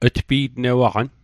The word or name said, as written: tpi'tnewaqan